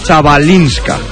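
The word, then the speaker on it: Chwalińska: Ha perdido Cristina Buxa, lo ha hecho en el doble 6-2 ante, a ver si lo digo bien, la polaca Chabalinska. – Pues le damos un abracito muy fuerte a Cristina Buxa, lo has dicho de maravilla.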